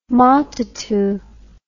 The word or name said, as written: matatu